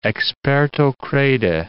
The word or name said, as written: experto crede